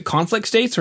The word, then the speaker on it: Conflict States: Did I say that right? Is it Conflict States, or do I have to take a pause because of the colons? – Oh, let's go. No, you got it just right.